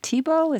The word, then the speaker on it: Thiebaud: But this is why you've been compared to Andy Warhol and other painters like, I'm thinking also of Wayne Thiebaud, is that how you pronounce Correct.